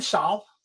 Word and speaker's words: Salle: I pronounce it Denton Salle. The E is silent. Okay. – Um, and it is the same word that is used for a fencing school or a, um, a savate um, academy.